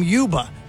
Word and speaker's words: Ljuba: I know a lady named Yuba who spells her name L-J-U-B-A, but we pronounce it differently.